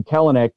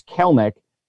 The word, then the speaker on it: Kelenic: And by the way, I was alerted that I've been mispronouncing his name. Rather than Kelenic, it's Kelnick.